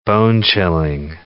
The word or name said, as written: bone-chilling